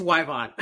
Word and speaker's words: Y-Vonne: Did I get the pronunciation of your first name correct? – Almost. It's Y-Vonne.